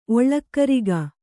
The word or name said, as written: ಒಳ್ಳಕ್ಕರಿಗ